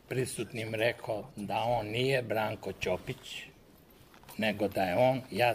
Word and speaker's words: Ćopić: Red je da ovom prilikom, kad govorimo o Branku Ćopiću, da pravilno izgovaramo to njegovo prezime. On nije Branko Ćopić, nego Branko Ćopić.